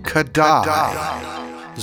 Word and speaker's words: Kodály: today's word is Kodály.